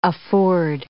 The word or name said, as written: afford